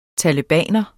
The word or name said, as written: talebaner